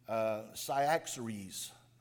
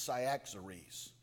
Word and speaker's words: Cyaxares: You've got a king from the Medes, and I think you pronounce the man's name Cyaxares. I think that's the way that, it's not Xerxes, but I think it's Cyaxares.